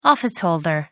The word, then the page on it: office holder: American English